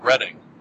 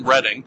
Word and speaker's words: Reading: See, it's spelled Reading, but I believe it's pronounced Reading. It's like a suburb of London.